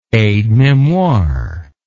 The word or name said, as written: aide memoire